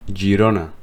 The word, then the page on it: Girona: Catalan